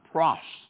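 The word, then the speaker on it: pros (πρός): This is really a word, pros, P-R-O-S would be the way that we would spell it.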